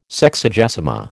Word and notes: Sexagesima: American English

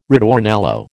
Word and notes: ritornello: American English

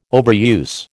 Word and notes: overuse: American English

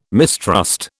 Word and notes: mistrust: British English

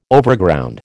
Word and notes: Overground: American English

